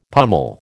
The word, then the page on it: pummel: American English